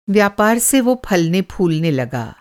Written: फलना-फूलना

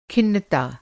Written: खिन्नता

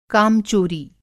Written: कामचोरी

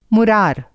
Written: मुरार

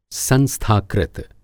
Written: संस्थाकृत

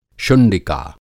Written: शुंडिका

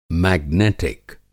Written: मेगनेटिक

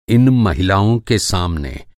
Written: सामने

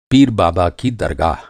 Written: दरगाह

